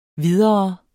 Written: videre

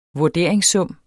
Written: vurderingssum

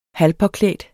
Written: halvpåklædt

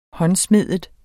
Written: håndsmedet

